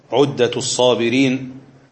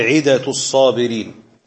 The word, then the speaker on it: عدة الصابرين: عدة الصابرين وذخيرة الشاكرين. وهذا الكتاب أحيانا ينطق اسمه بطريقة أخرى فيقال عدة الصابرين وذخيرة الشاكرين.